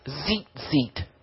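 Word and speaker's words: zeet zeet (ציצית): the word for tassel, I'll spell it for you, is pronounced zeet zeet. And it's T like Tom, Z like zebra, I T like Tom, Z like zebra, I T like Tom.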